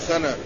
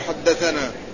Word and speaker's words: حدثنا: أما حدثنا فيكتب منها شطرها الأخير وهو الثاء والنون والألف، تكتب ثاء ونون وألف لكنها لا تنطق كما تكتب، لا يقال ثنى، وإنما تقرأ كاملة فيقال حدثنا.